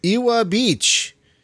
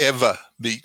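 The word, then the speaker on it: Ewa Beach: And my friends, we have a guest this week joining us from, I hope I say this right, Iwa Beach in Hawaii. You lucky sod. – It's actually Eva Beach. – Eva. Oh, so the W is pronounced like a V? – Yep.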